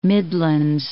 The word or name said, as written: Midlands